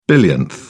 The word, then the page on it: billionth: British English